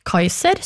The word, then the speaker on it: Cæsar: Ordet keiser er avledet av tysk kaiser, som igjen er avledet av Cæsar, som på klassisk latin ble uttalt omtrent som Kaiser.